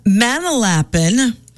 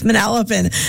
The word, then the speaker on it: Manalapan: originally from Manalapan. Not how you say it, by the way. It's Manalapan. I always remember because growing up, my our neighbor, Ira Silks, and they're every now and then they tune in.